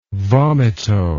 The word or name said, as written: vomito